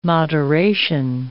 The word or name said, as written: moderation